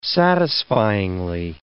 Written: satisfyingly

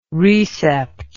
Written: recept

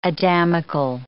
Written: Adamical

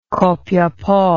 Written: copiapo

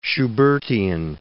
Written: Schubertian

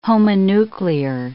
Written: homonuclear

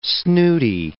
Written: snooty

